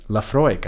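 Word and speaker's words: Laphroaig: We have a Lefroy. Is that how you say it? – Lefroy, I believe.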